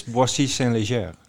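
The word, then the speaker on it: Boissy-Saint-Léger: hoe voel je mijn uitspraak? goed, maar weet je ook wat het is, Boissy Saint-Léger? – het is volgens mij een voorstad van Parijs.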